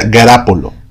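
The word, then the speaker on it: Garoppolo: No, se escribe Garópolo, pero se escribe, eh, se pronuncia Garápolo.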